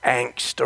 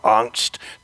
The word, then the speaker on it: Angst: But what do we do with, so many of us like to toss the German word angst around, or angst, depending upon where you are from in Germany as to how it's pronounced.